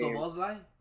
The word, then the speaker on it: Szoboszlai: Szoboszlai Yeah, there you go.